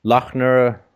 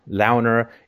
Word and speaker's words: Loughner: Now I've heard a bunch of pronunciations. Lochner, Launer. It's L O U G H N E R, which is interesting because if you spell it like Doe, with the O U G H silent, he actually becomes loner, which is actually, I think, quite appropriate in many ways.